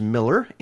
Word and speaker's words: Moeller: Speaking of that, coming up next is a Trop Rocker by the name of Gary James Miller. M-O-E-L-L-E-R is the way you spell that, Miller.